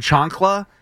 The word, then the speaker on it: chancla: A chancla? – Okay. – The home run chancla, C-H-A-N-C-L-A.